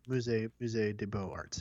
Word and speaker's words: Musée des Beaux-Arts: Des Beaux-Arts. It's French. B-E-A-U-X, arts. – Musée des Beaux-Arts. Yeah, there you go. – Pronouncing it as well as an English speaker probably can.